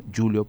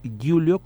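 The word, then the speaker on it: Giulio: Julio Plotino, por si ustedes quieren buscarlo, Julio como, como se escribe, G-I-U-L-I-O, y Plotino tal como suena, Plotino.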